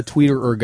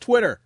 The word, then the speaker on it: Twitter: I'm just not a tweeter guy, I guess. Yes, I know it's pronounced Twitter.